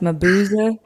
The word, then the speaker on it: Mabuse: I mean, I don't speak German, but I think it's pronounced Mabuse.